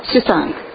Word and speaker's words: Suisun: Came to our club from the Fairfield, California, Suisun. Is that how you pronounce it? – Suisun.